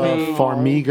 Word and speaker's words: Vera Farmiga: Vera Farmiga.